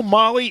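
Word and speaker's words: Mahle: It's pronounced Molly. It looks like male. It's Molly.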